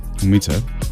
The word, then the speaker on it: Ουμίτσα: Χτυμέμαι το μεγάλο κόλπο, η μύθη του Βάλτου, Βαβυλώνα το κουαρτέτο των στοιχείων και X-Ray και Πρόσχα, πώς δεν το προφέρω σωστά, το Ουμίτσα. – Ουμίτσα, ναι. – Σωστός, πολύ καλός.